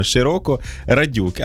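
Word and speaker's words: Широкорадюк: але насправді його неправильно вимовляють. Треба вимовляти окремими словами. Широко Радюк, а тоді все вдасться.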